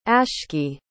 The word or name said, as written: ashkii